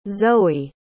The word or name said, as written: zoey